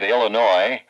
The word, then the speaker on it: Illinois: We've got heavy snow warnings for parts of Illinois, where the S is silent, Indiana and Ohio.